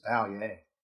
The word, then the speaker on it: Vallier: Yes, although it's quasi-Americanized. It's just Valier.